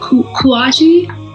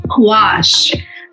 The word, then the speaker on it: gouache: Red has created bodies of work in acrylic, oil, and how do you pronounce that? Kouachi? – Gouache.